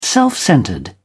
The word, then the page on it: self-centred: British English